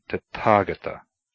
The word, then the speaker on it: Tathagata: Similarly, th, tha, is never pronounced like the English th in thin or that, but as aspirated t, as in Tathagata, a title of the Buddha.